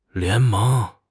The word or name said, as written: 联盟